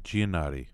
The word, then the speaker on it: Giannotti: Giannotti? How do you say his name? – Greg Giannotti.